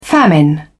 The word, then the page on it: famine: British English